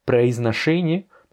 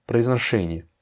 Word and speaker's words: Произношение: Произношение, bah, tu vois qu'il y a deux O, de proizno, eh bien ces deux O-là, n'étant pas accentués, se prononcent comme des A. Произношение.